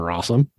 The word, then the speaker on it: Rossum: Because, I mean, you know, I know Alan Rossum. His fucking name rhymes with awesome, so I know how good he is.